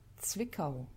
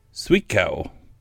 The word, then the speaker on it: Zwickau: to convert its Zwickau, Zwickau. Man, I'm sorry for everybody in Germany who's listening. Um, I have a little thing up here to pronounce it. Let's see what it says. Zwickau. Zwickau.